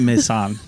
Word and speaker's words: Maysan: Oh, Paul's listening to this going, that is not how you pronounce that. – No, it's not how you pronounce it. I'm so sorry, Paul. – Probably Mazan. – Mazan? Okay. Yeah. Maysan.